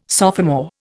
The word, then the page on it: sophomore: Indian